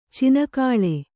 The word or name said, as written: ಚಿಣ್ಣಿಕೋಲು